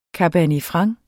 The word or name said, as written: franc